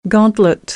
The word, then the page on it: gauntlet: American English; ɑː - ɔː